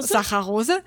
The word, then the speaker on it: Saccharose: Okay, also Dextrose, Glucose, Fructose, Maltose, Laktose, Saccharose, wie wird das eigentlich ausgesprochen? – Saccharose.